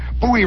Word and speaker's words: Bowie: They're up and running here Monday, Friday, January 11th at Bowie Race Course. – By the way, it says in here, rhymes with Dewey.